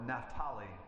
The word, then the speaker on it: Naphtali: Because it says he travels to the land of Zebulun and Naphtali, or Naphtali. I've heard that pronounced that way as well.